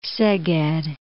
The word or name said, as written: Szeged